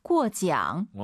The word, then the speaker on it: 过奖: 발음을 잘하셔야 돼요. 잘못하면 그 대한민국의 은어가 될 수 있어요. 꾸어입니다. 네, 꾸가 아니라 꾸어지앙, 꾸어지앙.